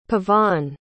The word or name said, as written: pavanne